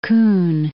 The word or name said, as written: Kuhn